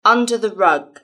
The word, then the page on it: under the rug: British English